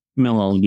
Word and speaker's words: Milholland: Milholland, yeah.